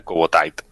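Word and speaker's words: Kubo Tite: Él a veces pone Tite, pero se pronuncia Cubo Taito.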